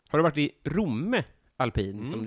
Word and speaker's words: Romme: Folk i Romme blir arga på att vi stockholmare kommer dit och tar deras pister, deras pyttepanna och uttalar deras ort fel. Romme heter det.